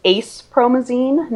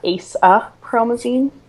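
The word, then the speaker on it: acepromazine: But actually, Scully mispronounces it. It's ace promazine, not ace a promazine.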